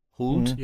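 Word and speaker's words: Hoult: Also ich habe auch schon überlegt, ist Nicholas Hult, spricht man mhm. – den so aus? Mhm. Nicholas Hult? – Hult? Mhm. Ja, genau.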